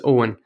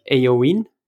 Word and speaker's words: Owen: Now, they did have pronunciation problems, so when they saw my name written down, it was more like Eowyn, they called me.